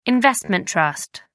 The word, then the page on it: investment trust: American English